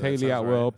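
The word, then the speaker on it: Hayley Atwell: Haley Atwell, did I get her name right? Haley? – Yeah, yes, Haley Atwell plays Peggy Carter.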